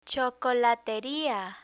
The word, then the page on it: chocolateria: spanish